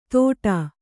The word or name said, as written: ತೋಟಾ